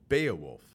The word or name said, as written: Beowulf